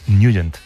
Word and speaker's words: Nugent: Siempre lo hemos llamado en el barrio Tednagent o Tednagent. – Claro, ¿verdad? Yo era de Tednagent. – Pero la pronunciación correcta es Nugent.